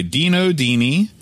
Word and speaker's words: Dino Dini: Kickoff, the first game in the series, was designed by Dino Dini. – I think that's how you pronounce his name. It is exactly correct, sir.